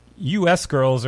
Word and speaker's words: U.S. Girls: Number All right, here is 2022 new music from U.S. Girls, or Us Girls, depending on how you want to pronounce it.